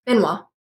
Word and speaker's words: Benoit: Let me bring, and I think I mispronounced your name. Is it Benoit or Benoit? – Benoit.